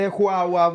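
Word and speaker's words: João: Har inte Joao wow, Cancelo varit väldigt bra eller? – Jo, han är med. han uttalas exakt så. Wow, wow, wow.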